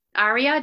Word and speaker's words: Arya: Arya, did I say that right? – Yes, my name is Arya.